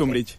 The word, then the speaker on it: Umbridge: ¿Umbridge? ¿Se dice así o cómo se dice? – Sí, Dolores Umbridge.